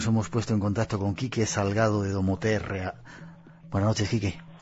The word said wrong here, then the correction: Domoterrae: Hola, ¿qué tal? Es Domoterrae. – Déjame que iba a decir, no lo he dicho bien, Domoterrae.